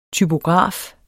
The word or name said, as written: typograf